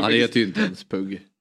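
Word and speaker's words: Pugh: Pug Rogefeldt. – Där måste jag rätta uttalet faktiskt. – Ja. – Pug. – Sa jag Pug? – Pug, sa du.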